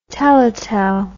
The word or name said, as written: teletel